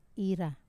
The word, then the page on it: ra: wamesa